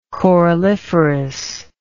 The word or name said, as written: coralliferous